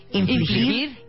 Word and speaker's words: infligir: Y explicamos lo que es la tortura, que es básicamente infligir, infligir, infringir, inflijar, ¿cómo se dice? infligir, infligir intencionalmente a una persona dolor o sufrimiento grave para obtener algo de esa persona.